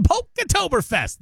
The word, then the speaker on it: Polktoberfest: Polka-toberfest. That's what it is. I'm really not doing real good at this right now. It's the Polktoberfest. There you go. So you got to say it like that.